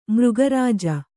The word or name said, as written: ಮೃಗರಾಜ